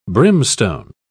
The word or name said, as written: brimstone